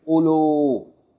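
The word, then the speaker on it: ulu (أولو): The first waw in this word, the first waw is not pronounced. So instead of saying ulu, don't make too long vowels. You say ulu, ulu. Short vowel, and then the second one is pronounced.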